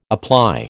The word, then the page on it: apply: American English